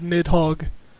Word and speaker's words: Nidhogg: It's called Nidhogg.